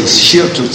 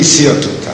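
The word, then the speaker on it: שרטוט: I don't know, some say shirtut, some say sirtut. Leflug itzirtut. I mean, it's like soretes. Itzirtut. And then Yerushalmi, it's spelled with a samach. So it's certainly sirtut. I mean, but some pronounce it shirtut.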